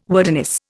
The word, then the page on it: wordiness: Indian